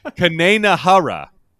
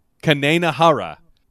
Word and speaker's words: Kaineinhoreh: What they are saying is, let me get the pronunciation right. Kanenahara. Kanenahara.